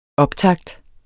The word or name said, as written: optakt